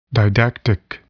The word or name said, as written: didactic